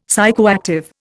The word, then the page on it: psychoactive: Indian